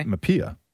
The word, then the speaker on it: Mapia: So in Greek, my name is spelled M-A-P-I-A because the letter P in Greek is R. – Okay. – Mapia.